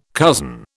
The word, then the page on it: cousin: British English